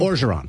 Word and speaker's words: Orgeron: Orgeron.